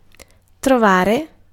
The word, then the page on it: trovare: /trɔ.ˈva.re/